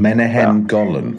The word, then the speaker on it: Menahem Golan: Menahem Golan.